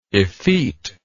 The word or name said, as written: effeteness